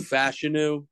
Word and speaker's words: Fashanu: Olu Fashanu is apparently how you pronounce his last name.